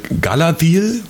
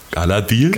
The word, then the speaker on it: Galadriel: Galadriel wiederum. Galadriel. – Galadriel.